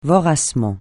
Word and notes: voracement: francais

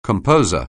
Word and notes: composer: anglais

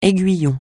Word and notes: aiguillon: français

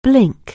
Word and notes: blink: anglais

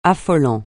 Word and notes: affolant: French

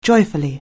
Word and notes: joyfully: anglais

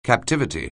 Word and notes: captivity: anglais

